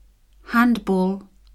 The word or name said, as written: handball